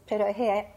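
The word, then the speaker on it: pirohe (пироги): So you made the pierogi as a child. Then. – Well, see, now the right way to say it is pirohe in Ukrainian.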